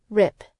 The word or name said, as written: rip